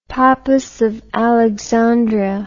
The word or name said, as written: pappus of alexandria